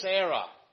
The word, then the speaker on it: err: We're doing a little series on answering religious error based on a statement in the book of Matthew 22, verse 29. You do err, E-R-R, that's pronounced err, by the way.